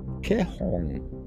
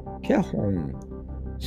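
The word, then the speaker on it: Caihong (彩虹): Symbols in the show, pronounced Caihong. Caihong. Spelled C A I H O N G, meaning rainbow, the title of, end your session on a high note with this vibrant pop song about hope and optimism.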